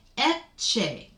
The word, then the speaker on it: Ecce: Ecce. Anytime a C is followed by an I or an E, it becomes a ch sound.